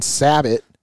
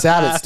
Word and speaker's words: sabot: And you're talking a muzzle loader, dude, 250 grain Sabot. – Sabo. – Sabo. I call them Sabots, dude. How do you say them? – Sabo. – No, it has a T in it.